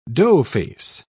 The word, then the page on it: doughface: British English